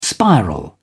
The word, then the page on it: spiral: British English